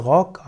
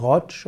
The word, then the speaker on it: Roch: Roch wird geschrieben R-O-C, er kann auch R-O-C, R-O-C-H geschrieben werden oder R-O-K-H. Er kann ausgesprochen werden als Rock, als Rotsch oder eben auch als Rock, Roch.